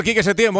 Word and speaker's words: Quique Setién: Entrenador Quique Setién, buenas noches. – Buenas noches. – Lo he dicho bien, más o menos, ¿no? – Sí, sí. – Sí, ¿no? – No, lo has clavado.